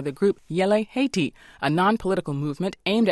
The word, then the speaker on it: Yele: Okay, the name is Yele, Y-E-L-E. – Yele.